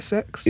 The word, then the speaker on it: CeX: I asked them how it's pronounced because I've been saying CEX for ages. It's like sex.